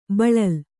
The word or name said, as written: ಬಳಲ್